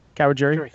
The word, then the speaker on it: Kawajiri: Am I saying Kawajiri? – That right? – Kawajiri. – Kawajiri.